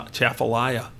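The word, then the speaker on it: Atchafalaya: We got off on the Chafalaya. I hope I pronounce it right. I always say it that. – Yep.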